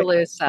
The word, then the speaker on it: Oskaloosa: So is from Oskaloosa. Did I pronounce that right? – Oskaloosa.